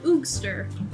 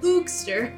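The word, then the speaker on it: Eugster: Ugister? Ugister? – Oogster. – Spell it. – It's E-U-G-S-T-E-R. Oogster.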